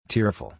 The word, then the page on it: tearful: ir